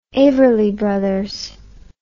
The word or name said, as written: everly brothers